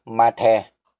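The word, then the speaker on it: mathe (מַטֶּה): mà cũng có thể mang nghĩa là gậy khi được phát âm là mathe.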